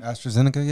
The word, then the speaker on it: AstraZeneca: AstraZeneca. – You said that right. – Yeah. Oh, you know the name already? – AstraZeneca, yeah.